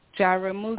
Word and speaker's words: Jaramogi: How do you say your last Jaramogi. – Name? – Jaramogi. – Oh, I really? Jaramogi. Yeah, I didn't say it right.